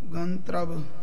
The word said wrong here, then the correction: गंधर्व: हां जी अद्धे रारे की आवाज आवेगी गंधर्व दे नाल पूरा रारा जिहड़ा है वह बोलिया जाता है सो गंधर्व इस तरह इनका शुद्ध उच्चारण है जी